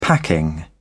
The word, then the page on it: packing: British English